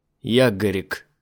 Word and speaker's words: Ягорик: «Ягорик». – Он сделал пронзительное ударение на первом слоге.